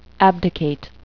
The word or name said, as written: abdicate